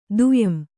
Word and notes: ದುಯಂ: Kannada

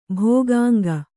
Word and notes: ಭೋಗಾಂಗ: bhōgānga

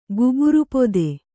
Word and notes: ಗುಬುರುಪೊದೆ: kannada

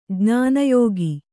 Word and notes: ಜ್ಞಾನಯೋಗಿ: jñāna yōgi

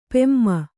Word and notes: ಪೆಮ್ಮೆ: kannada

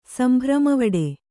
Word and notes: ಸಂಭ್ರಮವಡೆ: kannada

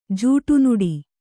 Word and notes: ಜಡಿನುಡಿ: jaḍi nuḍi

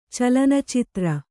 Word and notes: ಚಲನಚಿತ್ರ: calanacitra

